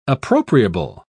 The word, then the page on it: appropriable: British English